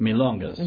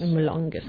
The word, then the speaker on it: milongas: How do you pronounce it? – Milongas. – Milongas. Mm-hmm.